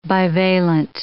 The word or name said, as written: bivalent